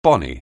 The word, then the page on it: bonny: anglais